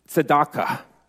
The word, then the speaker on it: tzedakah: It's Shemesh, which is the sun part, okay, and of righteousness, which is tzedakah. Shemesh tzedakah.